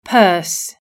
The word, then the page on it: purse: British English